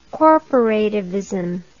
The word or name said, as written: corporativism